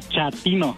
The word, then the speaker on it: chatino: Chapino. – ¿Chapino? – No, chatino. – Es como chato, ¿verdad? Chatino. – Sí, azunero, como chato, chatino.